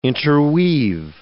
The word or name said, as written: interweave